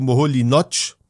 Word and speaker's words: Moholy-Nagy: wie man den ausspricht, Laszlo Moholy-Nagy, geschrieben Moholy-Nagy, so ein ungarischer Name.